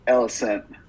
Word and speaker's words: Alicent: Behind the scenes where the actress, like, where the actress who plays Alicent, am I saying that right? Alicent? – Alicent. – Alicent.